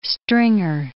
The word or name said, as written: stringer